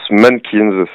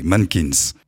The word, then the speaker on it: MNNQNS: Ça se prononce Mannequins, c'est comme mannequin, mais à l'anglaise. – Ok, Mannequins.